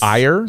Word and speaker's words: Eyre: Yes, but that's not how you pronounce the. Le- it's Eyre.